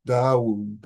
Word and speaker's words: Dawu: This is Yunyan and Dawu. That's how you pronounce their names in No, that's in Chinese, and in Sino-Japanese, they pronounce the name Ungan and Dogo.